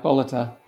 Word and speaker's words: Bolleter: Did I say that right? – No, Bolleter.